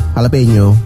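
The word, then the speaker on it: jalapeño: Jalapeno. – Hah? Bacanya itu ya? – Iya, jalapeno. – Kok tulisannya kan jala? – Kan kalau orang Meksiko, orang Latin ngomongnya gitu. J jadi H.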